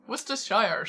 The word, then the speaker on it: Worcestershire: I think there's an extra syllable in there, you have to say, to prove you're... – so many... – To prove you're very British. – Yes. – Worcestershire sauce.